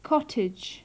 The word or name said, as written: cottage